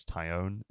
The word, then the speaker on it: Taillon: I think I'm pronouncing that correctly. It's hard not to call him Jameson Taillon, but I think it's Taillon, isn't it?